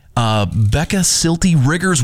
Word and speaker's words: Becca Syltie-Riggers: This year's Spotlight winner from Arts Missoula's Missoula on Main event, Becca Syltie-Riggers.